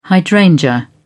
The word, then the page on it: hydrangea: British English